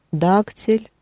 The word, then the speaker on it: дактиль: mm Дактиль. Ударение на первый слог значит, будет ударение на первый.